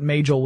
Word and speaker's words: Majel: And the project name at Google was Majel, or Majel, depending upon how you want to pronounce it, but Majel would be the way her name was actually pronounced.